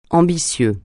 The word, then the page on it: ambitieux: francais